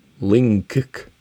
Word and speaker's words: Lyngk: They have the next in the series, which is Lyngk, L-Y-N-G-K. I don't think I can pronounce a G-K sound. Lyngk.